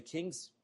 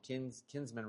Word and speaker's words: kinsman: Like his own family, because, you know, there's that redemptive factor of the king's folk, the kinsman redeemer. And my wife said that I was saying it wrong. Kinsman redeemer. I think I keep saying kings, but it's kinsman redeemer.